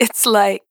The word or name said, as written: It's Like